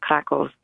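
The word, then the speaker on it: Kraków: I don't know if I'm pronouncing Krakow right. I never know how to pronounce. Is it Krakow? – That's an English pronunciation. If you want to go all Polish, you can call it Krakow.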